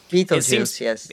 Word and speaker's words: Betelgeuse: Betelgeuse, it is pronounced Betelgeuse, like the film, but spelled differently.